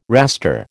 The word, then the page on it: raster: American English